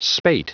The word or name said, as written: spate